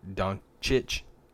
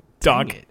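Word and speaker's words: Dončić: I think Doncic in Phoenix would be super. – He still said it wrong. – Doncic.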